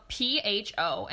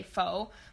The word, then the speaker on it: pho: And if you haven't had pho before, it's spelled it's spelled P H O, and it's actually a lot of people say pho, but it's actually pronounced pho.